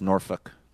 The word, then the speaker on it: Norfolk: Then I'm going to hustle off of the Outer Banks and then back to Norfolk, Virginia, which pronouncing that correctly is – I always feel like I'm being pretentious or weird, but I do believe it is Norfolk.